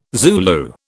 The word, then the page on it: Zulu: British English